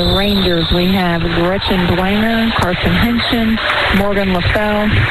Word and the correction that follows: Leffel: Leffel, I knew I said that wrong, Haley Flutter-John, Megan Jurassic, Carly Flutter-John, and Ella Gable.